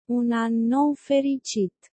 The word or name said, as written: un an nou fericit